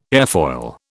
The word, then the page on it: airfoil: British English